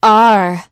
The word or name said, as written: are